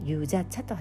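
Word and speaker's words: ゆ じゃ 茶 (유자차): ゆじゃ茶と発音するんです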